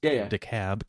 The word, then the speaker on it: DeKalb: Not too many days after Halloween, there was a loud neighbors in an apartment complex in DeKalb, spelled DeKalb, but pronounced DeKalb County. – No, really? Because we have DeKalb up here and we pronounce the.